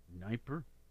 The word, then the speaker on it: Dnieper: It's spelled D-N-I-E-P-E-R. Dnieper? You forget the D and just say Dnieper? I think so.